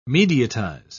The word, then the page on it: mediatize: British English